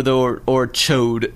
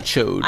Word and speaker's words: Ch'od: Or Chode. It's spelled Ch'od. – Let's say Chod. – Chod, not Chode. – I really don't know. – And not Cha'od or Cha'od.